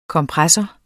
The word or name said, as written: kompressor